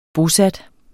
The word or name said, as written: bosat